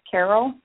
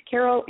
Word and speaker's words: Carroll: And Kate is spelled with a K, so that's K-A-T-E, yep. – And then her last name is Carroll, C-A-R-R-O-L-L. Yeah, C-A-R-R-O-L-L, right? – Yep, perfect.